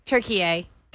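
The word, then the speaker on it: Türkiye: Yeah, it's not Turkey anymore. – Turkey-yay? – Turkey-yay.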